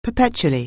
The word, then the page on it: perpetually: anglais